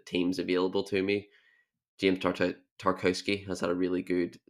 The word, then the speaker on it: Tarkowski: But I think Tarkowski, when he played, I've had an absolute stinker pronouncing his name twice. Let's go again. Tarkowski, when he plays, has been a leader on the pitch for them and been really, really good.